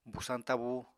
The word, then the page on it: busañ tabú: Apatani